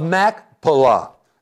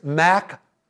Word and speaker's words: Machpelah: So something else here, and this brings us to our fourth point, and that is the cave of Machpelah. That's the actual way of pronouncing it. I've already killed it like three times. It's Machpelah.